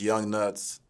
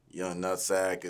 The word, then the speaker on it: Young Nuts: But it is Young Nuts. It is not Young Nutsack. It's not Young Nuggets. It's not none of that. But, yeah, that's how you say it. It's Young Nuts. Just spelled different.